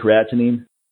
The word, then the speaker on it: creatinine: Creatinine? – And I've heard that. Yeah, is that how you say it? Creatinine? – Creatinine, yeah.